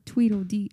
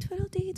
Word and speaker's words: Tweedledee: Tweedledee. It's because I want to say it like twiddle. Like I want to be like twiddle. And it's not even like that. Like you have to pronounce everything.